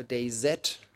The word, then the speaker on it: DayZ: Der berühmteste und aktuelle ist ein Online-Shooter, der heißt DayZ oder DayZ in der englischen Aussprache. DayZ, das amerikanische, also der Tag Z, Tag des Zombies.